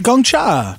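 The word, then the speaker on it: Gong cha: Can you imagine if I've been saying that wrong this whole episode? – Yeah. – No, I think it's right. – Gongcha. – No, it's right. Gongcha.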